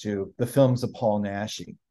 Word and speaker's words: Naschy: but Paul, is it Nashi? – Yes.